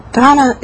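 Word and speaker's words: dāna: And Donna, it's spelled D-A-N-A, is the Sanskrit or Pali word.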